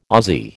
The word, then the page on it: Ozzie: American English